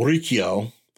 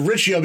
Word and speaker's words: Ricchio: My name is spelled R-I-C-C-H-I-O. Now, I understand R-I-C-C-H, what sound that is. That's a hard C-H. So technically, it's, you know, Riccio. But we've always said Riccio because we're in Maine.